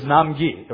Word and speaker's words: Nam-gi: My Korean name, pronounced properly, is Nam-gi, right?